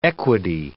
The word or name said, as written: equity